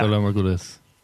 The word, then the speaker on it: Valar Morghulis: And he says, give this to somebody from where I'm from and tell them this name, the name that we can't pronounce, Valar Maka, whatever. – Morghulis. – And yeah. – Valar Morghulis.